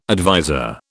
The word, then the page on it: adviser: British English